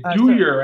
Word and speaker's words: Dyhr: Is it Dyer? D-H. – It's Dyer, actually. – Dyer, D-Y-H-R.